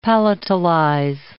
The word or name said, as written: palatalize